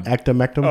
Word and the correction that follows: ivermectin: Oh, ivermectin.